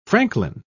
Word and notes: franklin: British English

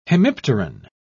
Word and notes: hemipteran: British English

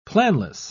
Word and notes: planless: British English

